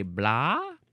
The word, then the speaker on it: blaa: It's a white bread roll associated with a specific town in Ireland, and they call it a blah. I want to look this one up. Blah roll. And I just think it's funny that it's spelled B-L-A-A.